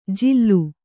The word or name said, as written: ಜಿಲ್ಲು